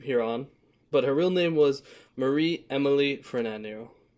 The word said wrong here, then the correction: Fornario: For Fornario. – I said it right the first time. I don't know why I did it wrong that time.